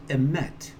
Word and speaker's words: emet: It's pronounced emet. And in the root of the Hebrew, this word emet doesn't have the en front. It's just met.